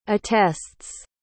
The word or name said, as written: attests